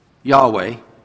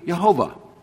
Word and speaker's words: Yahweh: The Lord, Yahweh, the name of God first used in Genesis, and it's pronounced, we think, Yahweh. They think that's most likely Yahweh. Or it could be Jehovah.